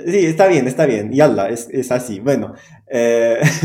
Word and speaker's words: yalla: Eh, tiene una entonación particular, eh, o sea, me suena que son pues dos sílabas, ya, la, ya, la. Mm. Entonces la entonación, por ejemplo, lo más fuerte es ya o la. Mm. Eh, no, sobre la primera sílaba yalla.